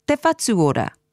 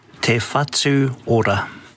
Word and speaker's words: Te whatu ora: Let's stay with ora and focus on the pronunciation of Health New Zealand, te whatu ora. – Te whatu ora.